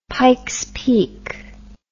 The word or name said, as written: Pikes Peak